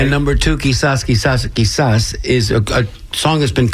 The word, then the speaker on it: Quizás: Quizás.